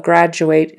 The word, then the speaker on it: graduate: And the verb is graduate, graduate. It, long A on the end of that word and that final syllable, graduate. Graduate. But graduate, graduate, like you are a person who has graduated, so you're a graduate, the noun, we do change that final syllable pronunciation to more of an graduate.